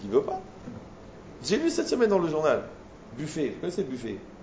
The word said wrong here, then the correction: Buffett: Ouais, Buffett. Ouais, Buffett. Buffett, c'est la prononciation.